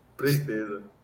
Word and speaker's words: presteza: Presteza. Prest, é prest, Presteza. presteza. – Então, eu falei certo, presteza, tinha falado certo. Não, não, não tem o segundo R.